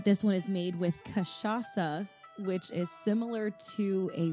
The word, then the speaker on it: cachaça: But this is the frozen caipirinha, and the spirit in it is pronounced cachaça.